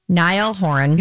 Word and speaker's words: Niall Horan: So she broke up with, I'm always going to say it wrong, Niall Horan.